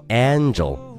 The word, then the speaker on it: angel: 所以说，天使这个词正确的读音应该是 angel，angel，angel，而不会读成 angel。